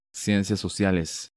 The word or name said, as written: Ciencias Sociales